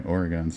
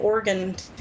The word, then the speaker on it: Oregon: Yeah, there's bumper stickers where they spell it... O-R-Y-G-U-N. – Nice. – Like Oregon. Oregon.